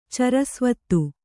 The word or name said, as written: ಚರಸ್ವತ್ತು